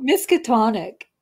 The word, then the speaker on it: Miskatonic: So Miskatonic, did I pronounce that right? – That's correct, yeah.